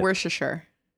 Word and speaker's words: Worcestershire: It's Worcestershire or what, however they say it. – Worcestershire.